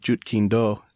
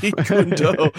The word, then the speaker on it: Jeet Kune Do: And I think if we're connecting the dots to what we talked about at the very beginning, when we were talking about Jeet Kune Do, did I just say that wrong? I did. – Close enough. – Jeet Kune Do.